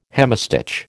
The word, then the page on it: hemistich: American English